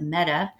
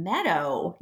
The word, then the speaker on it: Meadow: Some of my earliest memories are in Meadow, Texas, outside of Lubbock, which I think the residents actually pronounce it Meta. Mom always said, my dad told her he was from Meta, Texas. And the first time she came to town, she said, "Sonny, you're from Meadow, not Meta."